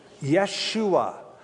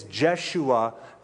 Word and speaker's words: Yeshua: Jesus' Jewish name is Yeshua. The high priest mentioned throughout this book is Jeshua. That's the English pronunciation of Yeshua.